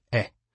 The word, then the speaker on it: haies: It was created, naturally, by the French, who needed some way to deal with the fact that four of the five letters in H-A-I-E-S, or hedges, were silent. That word is pronounced eh.